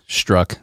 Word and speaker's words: Strzok: Struck is how they say it. Struck. It's dumb.